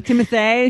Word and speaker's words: Timothée: Was it Jennifer Lawrence, Timothy Chalamet? – Timothée Chalamet, is that how you say it? – Yes.